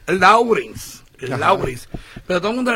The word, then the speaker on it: Lawrence: Se escribe Lawrence, pero se pronuncia Lawrence.